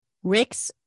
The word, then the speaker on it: Rix: Rick's Road. Now, my maiden name is Rick's. And it is spelled R-I-X.